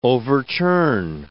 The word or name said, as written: overturn